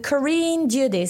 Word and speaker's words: Carine Dieude: So um, my handle is uh, Carine Dieude.